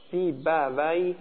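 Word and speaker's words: سیبویه: صوتی هم مثل سیبه ویه، سیبه ویه اینجوری تلفظ میشه.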